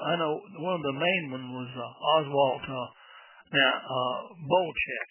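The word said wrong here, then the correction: Boelcke: The guys at Top Gun who adore him call him Oswald Bolke.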